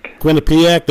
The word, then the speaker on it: Quinnipiac: Quinnipiac? Is that how you say that? – Quinnipiac.